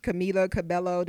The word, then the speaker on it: Camila Cabello: Top new artists, we have 21 Savage, Camila Cabello.